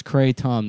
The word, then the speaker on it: kratom: And kratom is pronounced kratom.